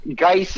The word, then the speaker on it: Geis: America, Darius Goose, Geis is how you pronounce it, but I'll call him Goose till he performs.